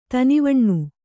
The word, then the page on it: ಧಾನ್ವನ: dhānvana